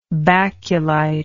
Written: baculoid

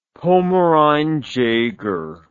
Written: pomarine jaeger

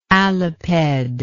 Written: aliped

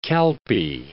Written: Calpe

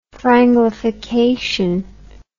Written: franglification

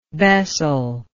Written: bessel

